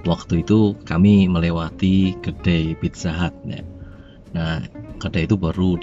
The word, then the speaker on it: Pizza Hut: coba membenarkan itu yang benar bacanya Pizza Hut loh tapi saya tetap ngeyel karena tulisan di sana itu tulisannya P I Z Z A H U T Hut bukan H A